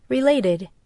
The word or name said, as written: relevant